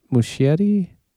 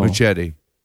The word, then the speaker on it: Muschietti: Andy, do you know how to say his name? No. Andy Mush- Muschietti? I don't know. Muschietti. – But he's Muschietti.